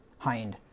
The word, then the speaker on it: hind: Is it hind or hind? – Hind. It's hind. I've said it wrong my entire life until Judy corrected me here a few weeks ago.